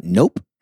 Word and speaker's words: Ngok: So the last name is spelled N-G-O-K, which according to my research, you pronounce as nope. – N-G-O-K? – Yes. – Yeah. N-G-U-Y-E-N is like Nguyen.